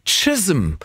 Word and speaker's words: Chisholm: Melanie Jane Chisholm. So wird es ausgesprochen. Das ist aber wirklich ein C, was da geschrieben steht und wird mit T ausgesprochen.